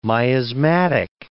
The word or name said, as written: miasmatic